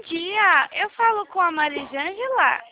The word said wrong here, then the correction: Mariângela: Não é Marisângela, é Mariângela. – Me perdoa pela pronúncia, Mariângela.